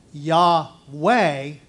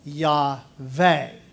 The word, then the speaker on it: Yahweh: So we pronounce it as Yahweh, but Jewish people pronounce it as Yahweh with a V.